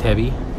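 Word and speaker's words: Täby: También hay, que decir que Tavi, Tevi se escribe TA con, con dos puntitos arriba,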